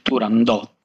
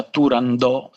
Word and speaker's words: Turandot: Io stesso sono nato e cresciuto con una pronuncia all'italiana, Turandot, e con la T finale nettamente pronunciata. Negli ultimi tempi c'è stata veramente una sorta di crociata da parte delle nuove generazioni contro chi pronuncia Turandot senza la T finale, quasi fosse, come dire, un retaggio di analfabetismo culturale, ecco.